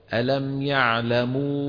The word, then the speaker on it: يعلموا: لا تجي من أنفك ألم يعلموا لا تقول يعلموا الغنة لا تكون في لغة العرب إلا مع الميم والنون فقط ولا تكون مع غير الميم والنون فأنت الآن تنطق واو ألم يعلموا هذه واو فما في معها غنة بالتدريب كله بيصير